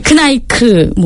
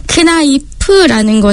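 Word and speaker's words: Kneipp: 사실 크나이프를 제대로 발음하시는 분들이 거의 없어요. 그래서 크나이크, 뭐 크나이트 이렇게도 많이 하시는데 저희는 항상 크나이프라는 것을 이렇게 크나이프 요법을 저희가 함께 이제 진행을 하는데요.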